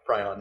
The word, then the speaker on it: prion: Pyron disease, just like mad cow. – Prion? – Prion. However you pronounce it.